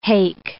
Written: hake

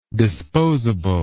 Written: disposably

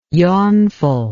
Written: yawnful

